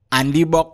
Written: ANDIBOK

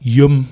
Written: Yom

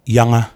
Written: Yanga

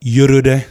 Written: Yorode

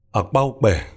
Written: Akpaukpe

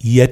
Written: Yet

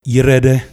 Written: Yirede

